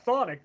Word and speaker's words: Thonic: On the list, it said Thonic. I think that's what the pronunciation was. I asked the label, I'm like, is that right? Thonic. That's the way they pronounce it.